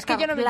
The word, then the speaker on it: Carlisle: En la serie se escribe Carlisle, que esto se pronuncia Carlisle.